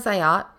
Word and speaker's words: Ayat: My name is Ayat, pronounced like the boat, a yacht.